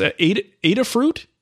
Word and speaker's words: Adafruit: Well, I'll put the link in the show notes there, but he said it's Adafruit. I guess that makes sense to pronounce it that way.